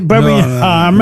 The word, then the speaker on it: Birmingham: Voilà, on ne prononce pas le G. Birmingham.